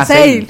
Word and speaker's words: sale: No, Sale. ¿Cómo se pronuncia? – Sale. – Como yo soy un poco mala para el inglés, sale.